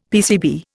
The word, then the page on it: PCB: Indian